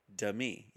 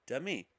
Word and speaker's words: Demi: Demi. Yeah, I got it right. Demi.